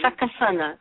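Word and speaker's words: Succasunna: Is it Suskasuna? Is that how you pronounce that city? – Sakasana. Sakasana. We have the Lenni Lenape Indians to thank for that.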